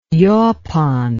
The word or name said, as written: yapon